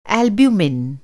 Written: एल्बुमिन

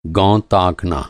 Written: गौं

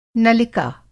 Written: नलिका

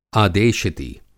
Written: आदेशिती

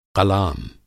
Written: क़लाम

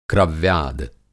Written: क्रव्याद

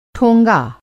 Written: ठोंगा

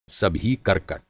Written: कर्कट

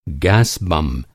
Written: बम

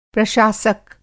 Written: प्रशासक

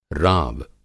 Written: राव

